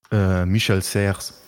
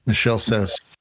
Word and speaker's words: Michel Serres: Michel Serres. – Michel Serres.